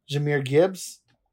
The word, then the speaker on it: Jahmyr Gibbs: You had uh, Jahmyr Gibbs.